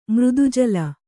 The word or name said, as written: ಮೃದುಜಲ